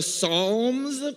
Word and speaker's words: Psalms: Here at WestU, we have been doing a series on the book of the Psalms, P-S-A, Psalms, the P is silent, whatever.